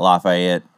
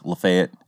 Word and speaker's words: Lafayette: Like, it's not Lafayette, it's Lafayette.